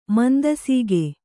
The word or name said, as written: ಮಂದಸೀಗೆ